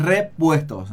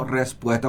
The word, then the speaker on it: repuestos: Pero es repuestos, no respuestos, como le escucho a veces decía a unos colegas, ¿verdad? Repuestos. Ahí la primera sílaba nos lleva a ese.